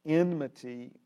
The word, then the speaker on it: enmity: Enmity to harmony. Now, what is enmity? It rhymes with enemy, and there are some relationships between the concept of enmity and enemy, right?